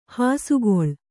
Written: ಹಸೆಗಲ್ಲು

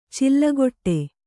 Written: ಚಿಲ್ಲಗೊಟ್ಟೆ

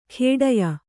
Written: ಖೇಡ್ಯ

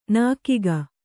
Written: ನೆಗ್ಗು

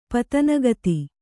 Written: ಪತನಗತಿ

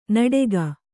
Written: ನಡಗೆ

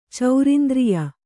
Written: ಚೌರಿಂದ್ರಿಯ

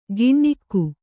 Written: ಗಿಣ್ಣಿಕ್ಕು

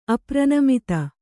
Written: ಅಪ್ರನಮಿತ

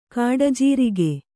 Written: ಗುಡಜೀರಿಗೆ

